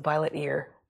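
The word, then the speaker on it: violetear: That's interesting that you pronounce it that way. I think of it as the violet ear because that's its name for the purple splotch on the ear, I think.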